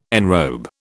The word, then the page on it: enrobe: British English